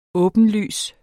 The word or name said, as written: åbenlys